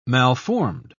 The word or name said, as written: malformed